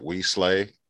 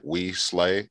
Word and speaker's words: Weslea: Her name is Wesley, but I call her We Slay. And because it's spelled WESLEA, so like We Slay, so I call her Big Slay.